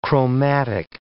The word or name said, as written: chromatic